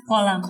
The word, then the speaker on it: Kolam: Kulam. You have Kulam? – Kolam. – Kolam. Whoa. You guys say... So Kulam? – Say Kolam. – Kolam. Dude, that is exactly how you say it in my language. In Malayalam, we say Kolam. Could you repeat it? – Kolam. – That's exactly Malayalam. In Tamil, it's written Kulam.